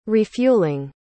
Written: refueling

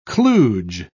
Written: kludge

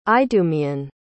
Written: idumaean

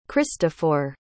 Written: christofor

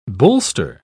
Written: bolster

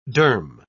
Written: derm

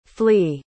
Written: fley

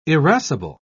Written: irascible

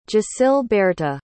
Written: gisilberhta

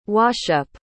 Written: washup

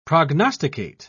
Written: prognosticate

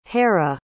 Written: Hera

